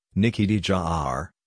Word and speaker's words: de Jager: Nikki de Jager, Dutch pronunciation, Nikki de Jager, born March 2, 1994, better known by her YouTube channel name Nikki Tutorials, is a Dutch makeup artist and beauty vlogger.